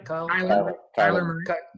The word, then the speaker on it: Kyler Murray: Distant second is Kyle Murray. Am I saying his name right? – Kyler Murray.